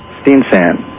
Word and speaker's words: Steensen: Robert Stenson. – How to say it again? – S-T-E-E-N-S-E-N. – You know, pronounce it for me. – Stenson. Danish.